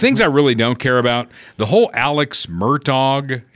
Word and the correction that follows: Murdaugh: am I saying that right? Murtaug? – Murdaugh.